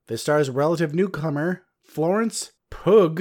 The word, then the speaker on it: Pugh: According to Google, it's pronounced Pew. P-U-G-H is Pew. There we go.